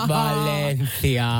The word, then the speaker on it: Valencia: Kaupunki Valencia. – Joo, ei, mutta siis toi oikein se kuuluu lausutelle Valencia.